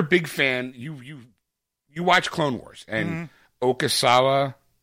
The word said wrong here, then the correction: Ahsoka Tano: Ahsoka Tano.